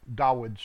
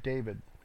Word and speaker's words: David: I keep saying David. I was raised with that, and it's hard to break that habit because I was raised with a mispronunciation, as were almost everybody. Nobody really knows it's Dawid.